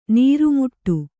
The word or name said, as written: ನೂರುಮಡಿ